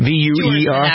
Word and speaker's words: Duerson: The family of David Viewerson. Is that how you pronounce this football player's name? V-E-V-U-E-R? – Duerson, actually. – What is it? – That's a typo. It's Duerson.